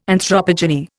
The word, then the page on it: anthropogeny: Indian